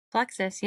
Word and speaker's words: Plexus: Plexus, yep.